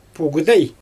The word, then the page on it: pugudEj: Xyzyl